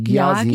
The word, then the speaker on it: Gyasi: Y-A-A, ja wahrscheinlich, und Giasi. – G-Y-A-S-I, Giasi. Ja, Giasi, würde ich auch sagen.